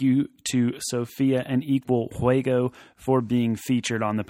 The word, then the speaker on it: Juego: And that's just for those of us who don't speak great Spanish, that's Juego, J-U-E-G-O, correct? – Yes, Juego. You don't really, it's kind of different to pronounce. It's Juego.